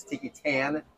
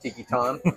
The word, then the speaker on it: Tiki Tan: We were told by the employees here that it's Tiki Tan. I kept wanting to French it up, Tiki Ton.